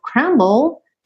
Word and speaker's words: crumble: Do you think I'd crumble? Do you think I'd lay down and die? Ok? Então, ó, crumble. Esse crumble, essas palavrinhas terminadas em BLE, DLE, lembra que esse E final não é pronunciado.